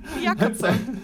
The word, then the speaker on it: Jacopo: Jacopo. Is that how you pronounce it? – I mean, in Italian, yes, it's an actual name, but I don't know how the game expects you to pronounce it.